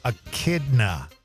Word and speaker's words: echidna: We did get a text on the actual pronunciation from Cam, the one who played the joke on us earlier. He says it's pronounced echidna.